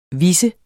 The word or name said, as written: vis